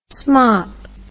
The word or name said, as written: smop